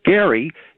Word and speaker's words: Gerry: Gerrymandering started right here in Massachusetts with Governor Gary, G-E-R-R-Y, and they pronounce it gerrymandering.